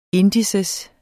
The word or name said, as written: INDEX